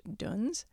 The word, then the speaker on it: dons: In Tibetan Buddhism, this is called duns. It's spelled D-O-N-S, but it's pronounced duns, and it means obstacles.